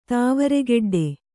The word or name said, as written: ತಾವರೆಗೆಡ್ಡೆ